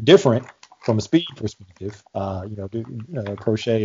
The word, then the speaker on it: Proche: James Prochet. And I had to look that up to make sure I got the pronunciation right, because I think I was calling him Proche, but it's Prochet.